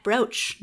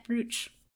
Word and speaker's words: brooch: I remember to ask this time, and it's cat brooch, not cat brooch.